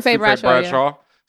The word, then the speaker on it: Sufe Bradshaw: Sufe Bradshaw.